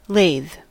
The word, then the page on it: lathe: /leɪð/